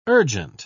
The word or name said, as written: urgent